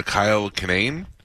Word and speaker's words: Kinane: I'm a latecomer to Kyle Kinane. Am I saying that right? – Yeah, yeah.